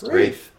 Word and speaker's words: Wraith: Oh, sorry, how's it pronounced? – Wraith. – Wraith? Ring Wraith, yeah.